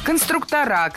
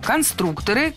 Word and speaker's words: конструкторы: Конструктора, конструкторов, это неверно. В слове «конструктор» ударение должно сохраняться на втором слоге, на «у». Поэтому конструкторы, конструкторов.